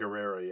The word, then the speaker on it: Gurira: Gurira. Gurira, yeah, I think yeah.